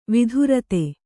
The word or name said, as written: ವೈಧೃತಿ